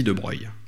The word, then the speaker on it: De Broglie: Alors, pour les gens qui se posent la question, comme Boris Chenot, pourquoi il s'appelle De Broglie alors que ça s'écrit De Broglie, c'est qu'en fait, sa famille est d'origine italienne et qu'en italien, ça se dit De Broglie.